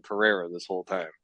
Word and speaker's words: Pereira: But if it's in the middle of their name, it's an R, like Alex Pereira. It's not Pajeta, like a lot of fucking frauds.